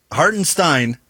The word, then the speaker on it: Hartenstein: Hartenstein is how you pronounce that one.